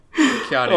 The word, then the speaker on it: Keanu: You call him Kanye Reeves. – John Yeezy. – The less popular Kanye. – John Weezy. – I've never pronounced his name right. Keanu.